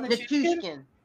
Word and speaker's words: Nichushkin: Um, can I also shout out Petruskin? Is that am I saying that right? Petrushkin Val Nichushkin.